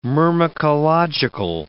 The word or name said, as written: myrmecological